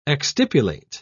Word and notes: exstipulate: British English

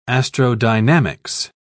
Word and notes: astrodynamics: British English

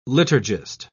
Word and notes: liturgist: British English

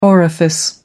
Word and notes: orifice: American English

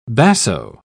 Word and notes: basso: British English

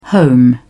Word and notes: home: British English

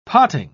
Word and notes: potting: British English

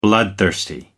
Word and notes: bloodthirsty: American English

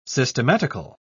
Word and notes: systematical: British English

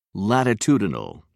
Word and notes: latitudinal: British English

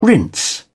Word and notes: rinse: British English

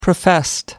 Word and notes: professed: American English

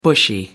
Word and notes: bushy: American English

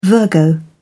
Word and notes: virgo: British English

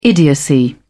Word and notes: idiocy: British English

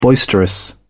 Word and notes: boisterous: British English

